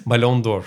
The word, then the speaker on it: Ballon d'Or: Ballon d'Or.